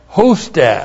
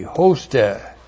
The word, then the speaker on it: hoste: Now we have hoste. H long O S T E, hoste.